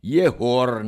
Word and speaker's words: Jehor: Tegemist on frikatiivse G-ga. Mida hääldatakse, otse kui H, ta on G ja H vahepealne, Jehor näiteks.